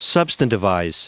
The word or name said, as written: substantivize